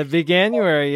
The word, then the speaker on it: Veganuary: Veganuary? – The Veganuary, yeah, that's great.